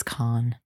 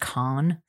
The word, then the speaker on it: Cannes: And Dawson will pipe in and be like, it's Con. It's actually pronounced Con.